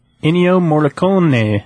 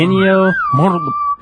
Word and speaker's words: Ennio Morricone: You're supposed to roll the R's. Can you do that? – I don't think so. – Ennio Morricone.